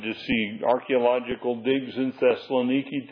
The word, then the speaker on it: Thessaloniki: Thessaloniki is the way they pronounce it today.